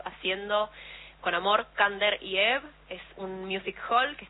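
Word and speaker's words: Ebb: Kander y Eve. – No, Eve, ay, siempre lo digo mal.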